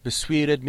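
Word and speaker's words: bestemed: All right, in line 23, we have Besuired mit swates gange, quilo mit cinche ye yurwed. – Notice the first word, besuired, has stress on the second syllable, so it's the second syllable, swul, that alliterates.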